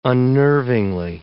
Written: unnervingly

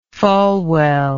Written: falwell

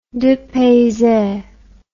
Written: depayse

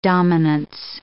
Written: dominance

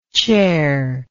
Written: chairless

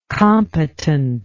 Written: competentness

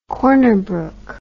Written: Corner Brook

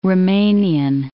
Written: Roumanian

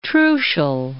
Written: Trucial States

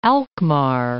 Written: Alkmaar

